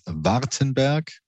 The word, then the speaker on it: Vordenberg: I pronounce it in German. It is Vordenberg, which is very hard. And I know in English, it sounds quite different.